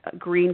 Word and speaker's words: Gruene: It's called Green, Texas. It's spelled G-R-U-E-N-E. It's German.